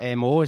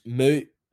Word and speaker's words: MØ: In the West, like in UK and in America, it's Mo. Like, that's the way she'll tell folk to say it. In Danish, it's like M with O with a line through it. But in Danish, you call that, it's pronounced Moo. But then if I was to say that, you'd say Moo, and it's like, no, the Danes are like, no, that's the sound a cow makes.